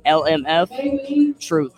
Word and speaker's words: LMF Truth: LMF Truth, T-R-U-T-H.